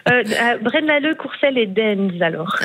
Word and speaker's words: Deinze: Et il y en a un, je m'excuse pour la prononciation, c'est Dinze. Ça s'écrit D-E-I-N-Z-E. – Ah, Dinze.